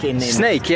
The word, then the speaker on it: Sneek: Yeah, you pronounce the snake like the animal, but it's written like S-N-E-E-K.